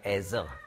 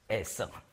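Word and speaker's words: Eser: Auf Deutsch schreibt man S, nicht wahr? Und ich sage Ezer. Es ist wie Französisch Zero, Null. Oder Englisch Zero. Ja, dieses, darum nicht unser scharfes deutsches S, Ezer, sondern Eben-Ezer.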